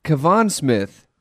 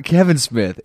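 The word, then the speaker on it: Kavan Smith: Kevon Smith. Not Kevin Smith, is 40.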